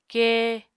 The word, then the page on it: kée: Apatani